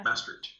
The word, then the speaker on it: Maastricht: Maastricht, yes.